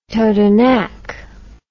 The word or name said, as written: Totonac